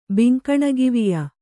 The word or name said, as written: ಬಿಂಕಣಗಿವಿಯ